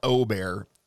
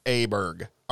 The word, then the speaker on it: Åberg: that is not how you pronounce his last name. It is not Åberg. It is Åberg.